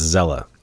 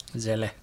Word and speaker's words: Seele: It's spelled SEAL, like S-E-E-L-E, but it's pronounced Zella. So... – Zella.